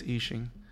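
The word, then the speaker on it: Isshin: For the record, I don't know if you can, how you pronounce it, but it's Ichin Kurosaki, if I can read it right. – Ichin. Okay. – Ichin. It's I2S, and then H-I-N. Kurosaki. – I would say it's Ishin.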